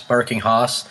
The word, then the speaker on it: Barking Haus: And that was from their 2008 release, Barking House, Barking Hoss, You Be the Judge. It's spelled B-A-R-K-I-N-G-H-A-U-S.